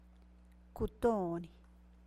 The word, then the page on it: kutoni: San Bartolomé Quialana Zapotec